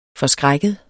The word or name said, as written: forskrækket